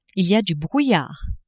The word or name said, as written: il y a du brouillard